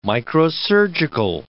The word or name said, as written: microsurgical